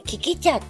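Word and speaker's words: 効喜茶: 昨日のことなんですけれども、はい、整体行って体を整える、ところに行ってきた時にキキ茶っていうのをね、効能の効に、喜ぶお茶と書いて、これは多分ブランド名だと思うんですけど、それでキキ茶って読むんですけど、キキ茶はどういうお茶かと言いますと、五行思想に基づいて 5 種類の葉っぱからできているそうです。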